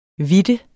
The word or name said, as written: hvidte